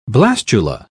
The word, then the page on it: blastula: British English